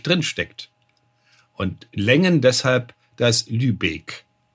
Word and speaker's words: Lübeck: Und deswegen wird Lübeck lang ausgesprochen, das E.